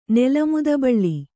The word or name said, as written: ನೆಲಮುದಬಳ್ಳಿ